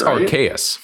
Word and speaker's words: Arceus: It's Arceus.